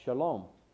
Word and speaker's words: shalom: In the Hebrew, it's a word that's pronounced shalom.